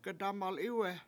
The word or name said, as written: gedAmalIwe7